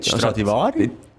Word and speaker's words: Stradivari: Nein, Stradivari.